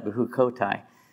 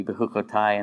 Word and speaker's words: Bechukotai: Bechukotai. And now, if you were, you pronounce the second to last syllable with the accent because it's the title. Otherwise, it would be Bechukotai, and that's a lot easier to say, at least for me.